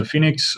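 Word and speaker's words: Féniks: But taking a Scandinavian or Afrikaans or Flemish version of it, it's spelled F-E with an acute sign, N-I-K-S, so Phoenix.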